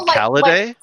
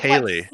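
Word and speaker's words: ceilidh: And I was like, Calliday? And they were like, Kaylee. Like C-E-I-L-I-D-G-H. – That was it. They Celidae. Yeah, I was like, Celidae? – It's pronounced Kaylee, and they're like, and it's the game that was it.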